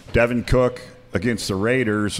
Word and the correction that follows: Dalvin Cook: Dalvin Cook.